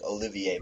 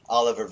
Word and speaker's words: Oliver: I always want to pronounce it French like Olivier, but it's Oliver Vernon, and then Snacks Harrison in the middle.